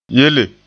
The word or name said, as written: yele